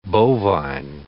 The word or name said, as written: bovine